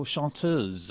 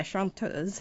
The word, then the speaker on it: chanteuse: Like, am I saying this right, the French word? – Oh, chanteuse. – Yeah, chanteuse.